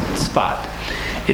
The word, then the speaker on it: Tzfat: We knew we would come back, but it was our goal to get to the town of Tzfat, which is difficult to pronounce, Tzfat. It's spelled, in English, we spell it S-A-F-E-D, Safed, it looks like. Let's call it Tzfat.